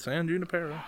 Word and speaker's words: San Junipero: They call it San Junipero in the movie, and it's spelled San Junipero. What would you call it? Junipero. Because mm, that's nope. what it is. – They don't call it that. Everyone calls it San Junipero.